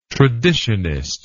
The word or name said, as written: traditionist